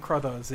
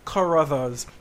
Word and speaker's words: Crothers: And it's funny because I always call him, when I think about him, his name isn't Scatman Crothers; it's Scatman Carruthers, because that's how they always pronounce it.